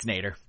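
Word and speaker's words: nadir: Nader. – I don't know how yep. to say it. Yep, it's Nader. – Nader.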